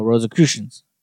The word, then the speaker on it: Rosicrucians: Oh, Rosicrucians.